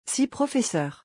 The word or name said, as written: 6 professeurs